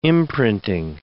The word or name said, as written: imprinting